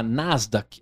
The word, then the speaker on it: Nasdaq: Tá afim de botar um dinheiro na Nasdaq? É assim que pronuncia? É isso mesmo.